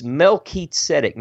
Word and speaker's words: Melchizedek: we pronounced that differently than I just did. We called uh, this guy Melchizedek, okay. Melchizedek, but that's just you know, but but it's Melchizedek.